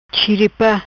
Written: Chiripa

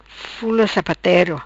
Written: Fula Sapatero

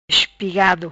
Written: Espigado

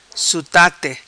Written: Sutate

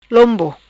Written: Lombo

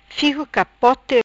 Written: Figo Capote

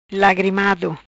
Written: Lagrimado

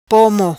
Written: Pomo

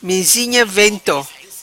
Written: Mizinha Vento

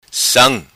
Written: Sam

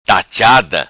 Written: Tachada

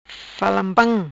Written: falanpam

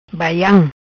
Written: Baiam

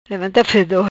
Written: Levanta Fedor

